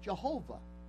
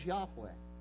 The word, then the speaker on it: Yahweh: And so for years, you had people pronouncing God's proper name as Jehovah. And there's questions whether that's really accurate. As they've done more study in the Hebrew language, they've actually come to find out that probably the most accurate pronunciation of God's proper name is Yahweh.